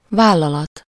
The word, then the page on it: company: /ˈkʌmpəni/